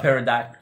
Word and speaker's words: pterodactyl: A pterodactyl. I pronounced the P. Okay. – Yeah, but you didn't pronounce the T.